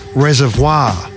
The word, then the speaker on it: Reservoir: It's out in Melbourne's north, Reservoir, which is spelt like reservoir, like in the Tarantino film. But for some local quirk, it gets Reservoir.